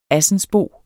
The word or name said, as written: ASSENSBO